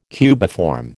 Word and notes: cubiform: American English

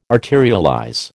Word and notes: arterialize: American English

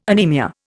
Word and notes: anemia: Indian

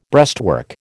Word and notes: breastwork: American English

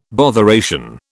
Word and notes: botheration: British English

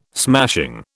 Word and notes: smashing: British English

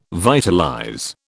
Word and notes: vitalize: British English

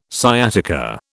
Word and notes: sciatica: British English

